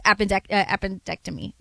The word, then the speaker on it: appendectomy: We can't say everything's changed just because they beat the Celtics in a game 7 after, you know, his appendectomy.